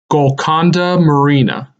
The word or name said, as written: Golconda Marina